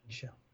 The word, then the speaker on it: Michel: Yeah, I believe he pronounces it Michel Haneke, but I don't know, but Michel, yeah.